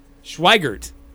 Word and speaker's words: Schweigert: Not Schweitzer. Schweigert. There we go. Bubba Schweigert.